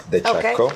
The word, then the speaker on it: DeCecco: It's DeCecco. DeCecco. – Okay, DeCecco.